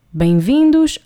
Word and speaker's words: bem-vindo: Bem-vindos ao Hotel Sabala. Obviously, that's plural, bem-vindos. If we were just talking to someone on their own, we would be saying bem-vindo. No S at the end, no sh sound at the end.